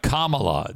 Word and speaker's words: Kamala: Sir, it's Kamala. That's how you say your name, Kamala. – Kamala. – Yeah, Kamala. – Let's do it together, Big Frud. Kamala. – No, Kamala. – Killing Kamala. – Kamala is how you say. You keep saying Kamala. – You keep saying Kamala. It's Kamala.